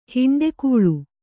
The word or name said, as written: ಹುಂಡುಕೋಳಿ